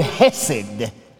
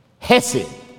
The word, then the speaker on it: chesed: It is in the Hebrew, the word hesed. Hesed. It is spelled with a C-H on the front of it in Hebrew. C-H-E-S-E-D. Hesed.